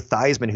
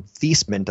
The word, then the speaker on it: Theismann: Joe Theismann, who changed his name from Theismann to Theismann to rhyme with Heisman.